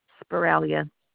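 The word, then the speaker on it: Sbaraglia: Yeah, Joanne Spiralia.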